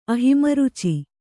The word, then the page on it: ಅಹಿಮರುಚಿ: kannada